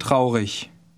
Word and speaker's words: traurig: Ich bin traurig. Traurig. – Notice the IG ending of the last word. It's a common ending, and it's pronounced like the word for I.